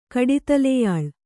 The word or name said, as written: ಕಡಿತಲೆಯಾಳ್